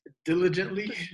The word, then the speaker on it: diligently: But that also doesn't stop you from working digitally, digitally, digitally, about digitally. How do you say that word? Diligently.